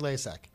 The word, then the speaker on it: Lasak: Greg Lasek. – L-A-S-A-K.